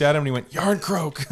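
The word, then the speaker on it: Jarnkrok: J-A-R-N. – You've already lost Marshawn. You've already lost him. – K-R-O-K. – Skittles. – And it's pronounced Yarncroke or Yarncroc.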